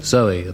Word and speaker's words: Zowie: It's called Kooks and celebrates the birth of a baby on Sunday called Zoe at the moment, spelt, of course, Z-O-W-I-E, to rhyme with Bowie.